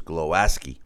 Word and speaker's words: Głowacki: On Saturday, former WBO cruiserweight champ Krzysztof Głowacki, or the way they pronounce it is Głowacki, improved to 30-1.